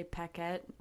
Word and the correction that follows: planchette: whatever it's called. – Planchette. I'm planchette, pretty sure that's how you say it.